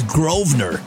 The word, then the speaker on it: Grosvenor: Anyways, um, listen to John, and his last name is Grosvenor. Don't pronounce the S when you call him; he'll get upset.